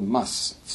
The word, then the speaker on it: mast: called MUST, spelled M-A-S-T. Sounds like mast, but it's pronounced must.